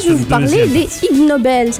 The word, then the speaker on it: Ig Nobel: Ig Nobel, ça s'écrit Ig Nobel. C'est comme deux mots, comme ignoble et Nobel.